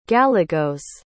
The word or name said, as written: gallegos